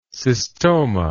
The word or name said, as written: cystoma